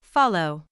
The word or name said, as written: follow